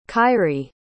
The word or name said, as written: kairi